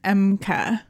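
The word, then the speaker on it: Ehmke: The H is silent, Emke. So it's just Emke.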